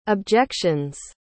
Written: objection's

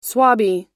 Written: swabbie

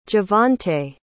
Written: javonte

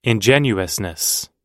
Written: ingenuousness

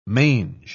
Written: mange

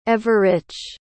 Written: everich

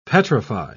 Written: petrify

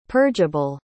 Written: purgeable

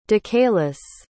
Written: decayless

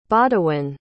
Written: bawdewyn

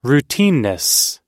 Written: routineness

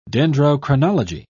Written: dendrochronology

